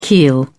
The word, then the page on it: keel: British English